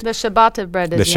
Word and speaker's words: ciabatta: Have you ever heard of ciabatta? Am I saying that right? This is the word, C-I-A-B-A-T-T-A.